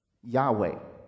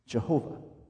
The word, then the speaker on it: Yahweh: And we think it was Yahweh is how we would pronounce this. And you probably heard that word before, Yahweh. And for certain historical reasons, the same word is also pronounced Jehovah. It's just a Latinization of Yahweh, I believe.